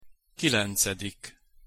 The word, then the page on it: kilencedik: Hungarian